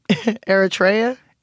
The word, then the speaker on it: Eritrea: attrition? – Eritrea? – Eritrea.